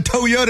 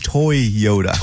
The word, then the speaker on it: Toyota: That's not how you say it. – You said that in a commercial? – I did. – That's funny. – And it's not. It's Toyota.